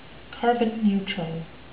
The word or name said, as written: carbon-neutral